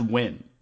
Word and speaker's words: Nguyen: Yeah, when it's Nguyen, like when it's the Nguyen, that's it's pronounced win, first of all. Like you win at sports, like win.